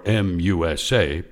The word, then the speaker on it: MUSA: or MUSA, pronounced Musa, At